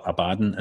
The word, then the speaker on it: Abaddon: By the way, I was going to say the reason why I call it Abaddon is because it's like a Games Workshop thing. It's a Warhammer thing. It's that's a pronunciation for that.